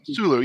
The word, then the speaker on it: Sulu: Sulu, yeah.